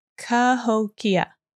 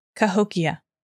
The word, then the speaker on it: Cahokia: It's Cahokia. Cahokia.